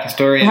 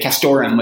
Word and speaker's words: castoreum: Castoreum. That's what I say. And other people pronounce it differently. I hear people say castorum without the E. That's how a lot of trappers say it.